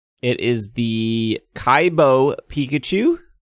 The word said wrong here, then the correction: Kibo: I think I was saying it wrong. I think somebody said it's Kibo because the I is the E. Where's Andrew? – It's Kibo.